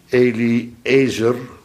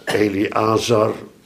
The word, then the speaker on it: Eliezer: Daarom heet de zoon van Aaron, de tweede hoge priester, Eliezer, Eliazar, hoe je het maar uitgesproken gehad had willen hebben, verandert er niks mee, maar Ezer, dat is het woord voor hulp.